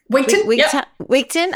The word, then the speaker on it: Wigtown: And I cannot wait to go to, is it Wigton? – Wigton. – I cannot wait to go to Wigton. – It's spelled Wigtown, but it's pronounced Wigton.